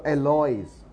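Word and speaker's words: Héloïse: 然后埃律斯呢，有的时候要看你怎么翻啊，因为呢法文通常 H 不发音，说 Alois，然后呢 O 上面有两点，表示 O 跟 I 呢都要发音啊，O 上面假如没有那两点啊，那就叫哇啊 a l o 啊，所以埃律斯有点道理。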